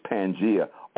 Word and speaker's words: Pangea: and that's spelled P-A-N-G-E-A, Pangea, just like it sounds, Pangea.